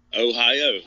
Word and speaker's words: Ohio: Ohio. That's how you say it.